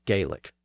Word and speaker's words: Gaelic: Now, the events in Scotland are important to the story of English because they eroded the traditional role of Gaelic in the government of Scotland. Most of Scotland spoke Gaelic, or Gaelic as it's pronounced in Scotland.